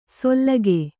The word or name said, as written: ಸೊಲ್ಲಗೆ